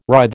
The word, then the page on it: writhe: American English